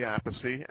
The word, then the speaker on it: Appice: My brother Carmine pronounces it Appice. I say Appice. And my other older brother, Frank, who doesn't play drums, says Appice.